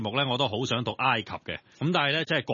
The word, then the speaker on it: 埃及: 咁读埃及呢原因係好简单嘅啫，因为我哋中文写出嚟土字边嗰个埃字呢喺粤语入面本身得一个读音嘅啫，就係尘埃个埃。咁但係点解我话埃及係应该读埃及呢，好简单，大家知道有一种东西叫氰化物㗎。係。